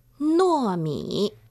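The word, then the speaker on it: nuomi (糯米): En chino se dice nuomi.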